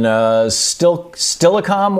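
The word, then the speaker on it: Steilacoom: Dave in Stillicom, Washington. Am I saying that right, Dave? – You got it, brother.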